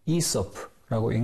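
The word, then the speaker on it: Aesop: 영어에서 AESOP로 표기하고 이소프라고 읽는데 그것이 일본어 번역본에서 이소포로 옮겨지고 우리에게 와서 이솝이 되었습니다.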